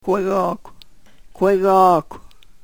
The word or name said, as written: kwegokw